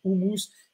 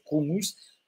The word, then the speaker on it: houmous: Alors les gens disent houmous, mais la vraie prononciation à la façon libanaise, c'est houmous.